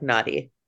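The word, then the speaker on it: Nadi: And like literally pronounced, it's N-A-D-I, but pronounced kind of like naughty.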